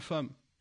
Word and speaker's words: femme: Il est où le A dans femme ? C'est E2M. E2M, il se prononce A.